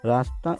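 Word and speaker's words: रास्ता: "Rasta" is pronounced as "ra sa ta",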